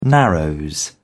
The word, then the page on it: narrows: British English